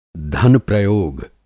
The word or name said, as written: धन-प्रयोग